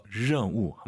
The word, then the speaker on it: 任务: 알 발음 런 아니고 더 혀를 뒤로 보내서 任务 한 번 더.